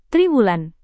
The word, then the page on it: triwulan: Indonesian